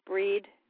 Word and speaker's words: Brigid: So basically, we celebrate Breed, which is spelled Brigid, but it's pronounced Breed in the Celtic pronunciation.